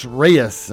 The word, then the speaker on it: Reyes: Turning 28 today is Alex Reyes, and I did pronounce that one correct.